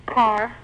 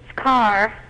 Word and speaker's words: Karr: Carr. – Could you pronounce the last name for me? – It's Carr. – K-A-R-R.